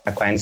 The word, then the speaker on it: Acquine: Aquine se escreve A-C-Q-U-I-N-E.